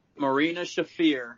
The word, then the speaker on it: Marina Shafir: Marina Shafir. Is that how you say it? There you go. Yep.